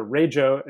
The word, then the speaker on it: Rego: and it turned out that that Rego actually means, or Rajo as it's pronounced, means car registration in Australia.